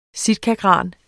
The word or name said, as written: sitkagran